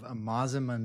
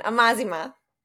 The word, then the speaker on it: Amazima: How'd I do with that pronunciation? – You said it right. – Corrected on that? – All right, no, cool. It's a hard one. Amazima. – Amazima.